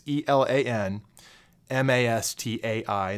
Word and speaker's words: Elan Mastai: I actually have no idea how Ilan pronounces his name. It's Elan Mastai.